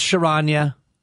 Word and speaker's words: Charania: I think it's Charania. It's a soft sh.